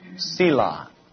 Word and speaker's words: Selah: Selah. We spell it S-E-L-A-H. Though in the Hebrew, it's only consonants. There's no vowels in it in the Hebrew. And so Selah is an assumed pronunciation, though we don't know if that's correct or not.